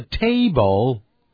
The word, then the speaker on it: table: 接着我们看第二行， there's a mouse on the kitchen table，在厨房的餐桌上，这个 table 您看是不是有一个 L 结尾， T-A-B-L-E，所以念成 table，你看我念再念一遍 table，的音呢舌尖一定要呢抵在上齿的指上排牙齿的后边，